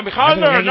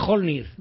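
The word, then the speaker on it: Mjölnir: Una M, una M muda ahí al principio. – Eso te iba a decir yo, con tu pronunciación es que era un poco chiquita. Mjolnir, no puedo. – Es Mjolnir, una M y después Mjolnir.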